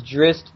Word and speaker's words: Drizzt: According to Wikipedia, the pronunciation is Drist Do'Urden. – Yeah, because they make fun of it in the book. A little kid sees him and they're screaming, Drizzt, Drizzt.